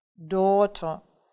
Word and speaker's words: daughter: Daughter. – Дочь. Посмотрите, да, как много здесь букв, а читается это всего 4 звука.